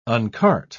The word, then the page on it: uncart: British English